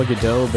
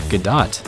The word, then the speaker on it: Gadot: I've always said Gal Gadot, but I think it's Gadot, and it's a revelation. Having to pronounce the T at the end of that is like... – Well, because it's not a French name.